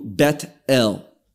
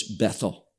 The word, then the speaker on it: Bethel: As in Hebrew, Bet-El. But oftentimes it's pronounced in English, Bethel.